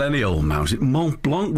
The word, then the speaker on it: Mont Blanc: Mont Blanc.